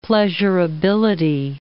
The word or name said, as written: pleasurability